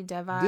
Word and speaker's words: Devi: But her name on the show is, like, I don't know if I'm saying it right either, but it's D-E-V-I, like Devi, Devi. – Yeah, Devi.